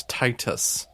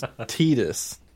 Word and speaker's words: Tidus: That's Titus. – Titus. – This is Tidus.